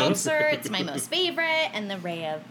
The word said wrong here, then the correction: Reka: is that how you say it? – Reka. – Mm-hmm. Reka vodka.